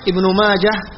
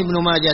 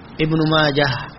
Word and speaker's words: ابن ماجه: أما كلمة ابن ماجه فتنطق بالهاء وصلا ووقفا، يعني لا تكون ابن ماجه تاء أو تو، لا لا، ابن ماجه هاء ما فيها نقطتين، وصلا ووقفا هكذا اسمه.